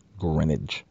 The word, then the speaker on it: Greenwich: Greenwich. Silent W. The widest letter in the alphabet is silent. Greenwich.